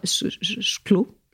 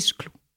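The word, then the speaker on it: Szkło: Je vais dire Shklo. Ça veut dire le verre en polonais. – Ça s'écrit comment? – Ça s'écrit S-Z-K-L-O. Mais je crois que c'est Shklo. – S-Z-K-L-O.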